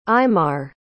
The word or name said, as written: eimar